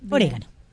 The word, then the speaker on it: orégano: Orégano. – Sí. – Así con el acento en la E.